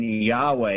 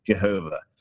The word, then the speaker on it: Jehovah: The evidence for the pronunciation Yahweh is very poor and totally insufficient to overthrow the powerful and numerous evidences in favor of the pronunciation Jehovah. Thus, it is evidence that Jehovah is the correct pronunciation of the name of God. Jehovah has not allowed the pronunciation of his name to be lost.